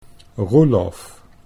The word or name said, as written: Roelof